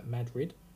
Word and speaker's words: Madrid: So if you are in Anson, Avon, Carabasset Valley, Carthage, East Central Franklin, Freeman Township, Kingsfield, Madrid Township, pronounced Madrid. No, not like the city in Spain. I immediately called it like Spain.